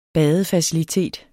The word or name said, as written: badefacilitet